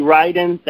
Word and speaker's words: Riden: My name is Jeremy Ryden. That last name is spelled R-I-D-E-N.